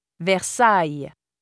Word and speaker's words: Versailles: IL, ILL, or ILLE following a vowel are pronounced as y, Versailles, Marseille, Montreuil.